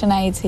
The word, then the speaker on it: Sinead: And it's spelled differently, Sinead, but it's pronounced in the Irish Sinead, is that right? – Yeah, it's Sinead, yeah.